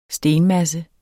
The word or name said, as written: stenmasse